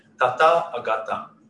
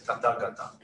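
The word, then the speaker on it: Tathāgata: Yeah, because the there's a long a here. Oh, there's a long a here. So depending on how you break it, it can be tata agata or tata gata. Uh, but usually translated as tata gata.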